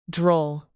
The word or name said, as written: droll